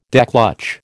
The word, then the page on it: declutch: American English